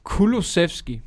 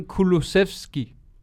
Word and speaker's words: Kulusevski: Kan vi, kan, må jeg få din udtale af Kyrsnevski? – Ja, Kulusevski. – Kulusevski? – Dejan Kulusevski.